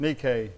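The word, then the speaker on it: Nike (νίκη): You don't really pronounce it Nike in the Greek. It's Nike. You want to make a friend of that word by saying it? Nike. N-I-K-E.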